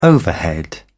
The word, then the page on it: overhead: British English